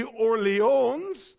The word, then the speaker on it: Orléans: So at the age of 17, Calvin moved to what we would pronounce as Orleans, but in French it'd be Orléans, to study civil law.